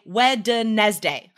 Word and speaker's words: Wednesday: So every time I write the word Wednesday, every time you guys write the word Wednesday, in your head, say Wednesday, right? W-E-D-N. That makes no sense. We don't say it like that.